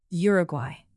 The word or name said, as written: uruguay